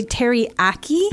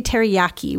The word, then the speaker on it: teriyaki: You can say teriyaki. – Mm-hmm. – You can also say teriyaki with more of a Y sound. – Yeah. – Both are fine.